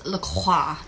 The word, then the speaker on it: LaCroix: Apparently, it's LaCroix. – Well, that's the French way.